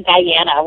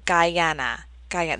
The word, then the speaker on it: Guyana: She was born in Guyana, which is in South America. – Oh my gosh. See, I apologize. – It's that little country right above Brazil.